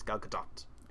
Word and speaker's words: Gadot: But it was one of them, and they said it, and she goes, "Oh, it's good, but you need to put a little more emphasis on the T. It's Gal Gadot."